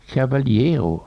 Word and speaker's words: cavaliero: Och han hade sjungit en sång som heter När jag var någon cavaliero. Alltså inte med B utan V. B uttalas som V alltså. Cavaliero.